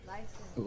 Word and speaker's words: Lifeson: Lifeson.